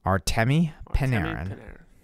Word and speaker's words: Artemi Panarin: Artemi Panarin is a New York Ranger.